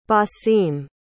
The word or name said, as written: basim